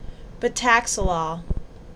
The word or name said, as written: Betaxolol